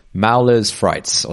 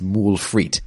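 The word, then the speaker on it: moules frites: And if you're like, I don't know, if you're English or something, you'd probably, you know, if you see that written down and you were pronouncing in English, you'd probably say like Mauler's frites or something, right? But you're not going to do that. You know, you know, it's roughly pronounced moule frites.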